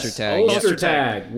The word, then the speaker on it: Ostertag: Ostertag.